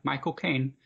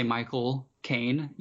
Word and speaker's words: Michael Caine: Well, isn't the cheat to it is to say his name, but don't say Michael Caine. You say my, as in possessive, cocaine, the drug.